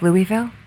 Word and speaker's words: Louisville: No, it's Louisville. – Louisville. Yeah, she's got a shirt that has a different phonetic. – Yes, it's got different ways. – It's got a phonetic key on there. – to help people so they pronounce it correctly. It's right.